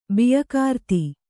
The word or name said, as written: ಬಿಯಕಾರ್ತಿ